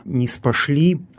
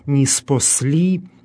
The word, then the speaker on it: ниспосли: Это слово «ниспосли», по-русски говорим «ниспошли», «ниспошли» действительно произносится проще, чем «ниспосли». Ну и если приставку убрать в этом слове, то разница произношения в русском языке и в церковнославянском языке будет такая же: «посли» по-церковнославянски, «пошли» по-русски.